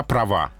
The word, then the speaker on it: права: Она права. Ударение убегает на последний слог.